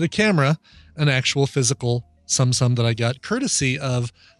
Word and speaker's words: Tsum Tsum: So I have recently gotten hooked on a game style called Tsumsum, but it's spelled T-S-U-M, T-S-U-M.